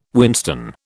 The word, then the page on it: whinstone: British English